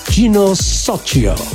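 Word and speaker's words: Gino Soccio: Había muchas dudas, pero bueno, logramos averiguar cómo se pronuncia en italiano y es Gino Socio.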